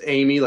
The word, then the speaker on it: Emigh: It's pronounced Amy, like the girl's name, but it's spelled Emigh.